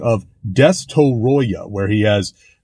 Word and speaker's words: Destoroyah: Now, regarding the name Destoroyah, in Japanese, his name is pronounced phonetically, and in the dub, he is called Destroyer. So, if you listen to the Japanese, when they say Destoroyah, if you listen to the dub, the international dub, they say Destroyer.